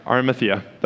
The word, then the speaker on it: Arimathea: Let's just say I said the name right, Arimathea.